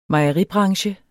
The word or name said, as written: mejeribranche